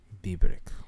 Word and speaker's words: Biebrich: S-C-H-L-O-S-S. That's the first word. And the second word is B-I-E-B-R-I-C-H. – Biebrich.